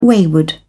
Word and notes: wayward: British English

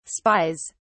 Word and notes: spies: British English

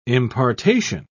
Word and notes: impartation: British English

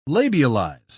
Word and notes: labialize: British English

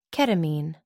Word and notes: ketamine: American English

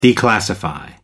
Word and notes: declassify: American English

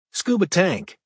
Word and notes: scuba tank: American English

